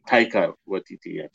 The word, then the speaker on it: Taika Waititi: It's Taika Waititi. It's like Tiger, but Taika.